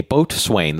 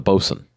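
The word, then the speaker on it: boatswain: It swain is not pronounced swain. – Oh. – So if you have a boat swain, that's the bosun.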